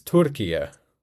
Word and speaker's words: Türkiye: I'm looking online, apparently it's Türkiye with an emphasis on the first one.